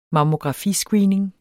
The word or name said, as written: mammografiscreening